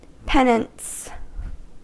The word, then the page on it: penance: American English